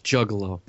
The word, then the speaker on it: juggalo: No, it is not being a gigolo from ICP. – It's pronounced juggalo.